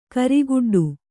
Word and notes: ಕರಿಗಿಡ್ಡ: karigiḍḍa; Kannada

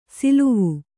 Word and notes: ಸಲವು: Kannada

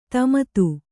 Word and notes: ದಮಥ: Kannada

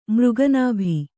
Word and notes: ಮೃಗನಾಭಿ: Kannada

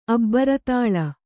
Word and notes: ಅಬ್ಬರತಾಳ: Kannada

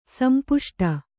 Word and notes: ಸಂಪುಷ್ಟ: Kannada

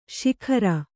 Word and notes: ಸಗ್ಗಾರ: saggāra

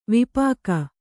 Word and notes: ವಿಪಾಕ: vipāka

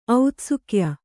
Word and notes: ಔತ್ಸುಕ್ಯ: autsukya

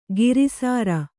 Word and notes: ಕೃಸರಿ: křsari

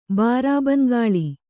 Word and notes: ಬಾರಾಬಂಗಾಳಿ: bārābangāḷi

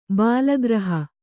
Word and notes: ಬಾಲಗ್ರಹ: bālagraha

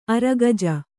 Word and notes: ಅರಗಜ: Kannada

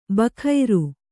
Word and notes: ಬಖೈರು: kannada

